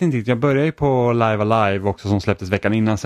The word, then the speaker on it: Live A Live: Så att det heter Live Alive. Det är liksom det korrekta uttalet, men det var liksom, jag har ju hört så mycket olika.